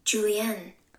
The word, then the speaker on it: julienne: The word is spelled J-U-L-I-E-N-N-E. And we were kind of like, is it this or is it this? But this is what it is, if you can hear it. – Julienne. – One more time. – Julienne. – So that's how you pronounce it.